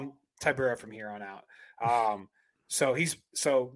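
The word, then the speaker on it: Tybura: I'm going to call him Biggie Boy Rosenstruck versus Tibera. – This is just a horrible card pronouncing names. Horrible up and down. – Tibera. That's how you say his name.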